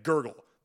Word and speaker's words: GRGL: That is a Halloween-style show with Social Stigma, Mauled, Lilith, Sutures, Mordecai Tempo, and Gurgle. That's spelled G-R-G-L. Gurgle.